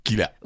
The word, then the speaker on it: Kyila: K-Y-I-L-A. Mais si vous écrivez K-I-L-A ou bien quoi là, elle va accepter. L'essentiel oui. – qu'on prononce Killa.